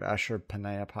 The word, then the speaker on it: Ashurbanipal: the Library of Ashurbanipal.